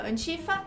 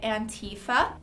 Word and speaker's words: antifa: Em inglês a gente tem duas pronúncias aí que o pessoal debate um pouquinho. A forma que eu falo é antifa, repeat after me, antifa. Mas tem alguns outros lugares, outras pessoas que podem pronunciar Antifa. One more time, Antifa. So we have Antifa or Antifa.